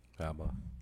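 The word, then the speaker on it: Habba: Alina Haba. I believe that's how it's pronounced. – Haba.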